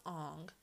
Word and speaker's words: Aang: In this movie, Aang is pronounced Ong.